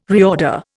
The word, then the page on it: reorder: Indian